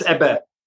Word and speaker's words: Ebbe: It's the smallest living cell all the way up into this like massive mirrored, well, it's called Ebbe. Is that how you say it? Is it Ebb or Ebbe? – It's Ebbe, yes.